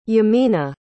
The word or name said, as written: yemina